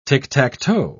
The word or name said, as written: ticktacktoe